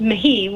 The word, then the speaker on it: Mahee: Mahi is actually, it's actually Irish. So it's just kind of like McGee, but it's pronounced Mahi with M-A-H-E-E.